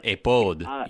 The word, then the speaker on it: ephod: I think we have to pronounce it actually now, according to the Hebrew, ephod.